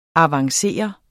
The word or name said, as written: avanceret